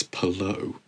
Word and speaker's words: Palou: It just sort of, it's Alex Palou. Say it correctly.